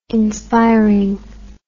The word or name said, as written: inspiring